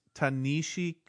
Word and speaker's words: Ta-Nehisi: Just to confirm, it's Ta-Nehisi Coates, I believe is how you say it.